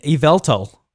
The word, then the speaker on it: Yveltal: In Yveltal, yes, I got the pronouncing right that time.